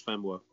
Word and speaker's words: Famuwa: Partly, well, I was saying Famuwa. Is that right? Famuwa. – He doesn't pronounce the E, so it tends to be pronounced Famuwa.